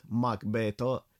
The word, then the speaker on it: Macbeth: Trebuie să atrag atenția încă de la început asupra faptului că în operă numele sunt italienizate, astfel încât numele Macbeth va fi pronunțat Macbeto,